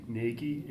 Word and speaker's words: Nagy: Casey Nagy, N-A-G-Y.